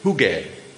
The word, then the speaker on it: hygge: Hygge, right? – What is that? – Hi. Apparently it's not meant to rhyme with piggy.